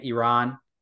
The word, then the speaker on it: Iran: Iran, as it probably should be pronounced.